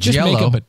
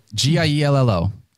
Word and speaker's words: Giello: Giello. Giello. Michael Giello. – Giello. – Yeah, Michael Giello. – Is that fair? – Michael Giello. Just say it with an Italian accent and maybe it's correct.